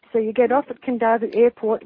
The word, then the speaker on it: Kadavu: There's an island called Kandavu, and that's spelled Kadavu.